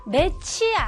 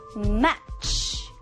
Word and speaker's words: match: Match. 매치 아니에요. 다시 한번 발음. Match. 끝에 취는 아주 시크하게 조금 들어가는 거예요.